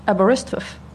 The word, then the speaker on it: Aberystwyth: Aberystwyth. In a little seaside village that sounds very easy, Aberystwyth, but when you see it spelled out, it's like Szczurek.